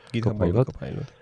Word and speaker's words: Copilot: Copilot.